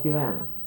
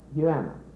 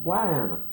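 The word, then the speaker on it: guano: And it was sent to this country so that the farmers and the rural people called anything that you bought guana. And the very ignorant used to pronounce it Guyana.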